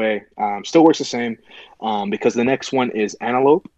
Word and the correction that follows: Antiope: How do you say it? Antiope? Okay. Antiope, she's a captain, 40 points.